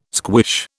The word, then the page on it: squish: British English